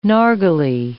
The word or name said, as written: narghile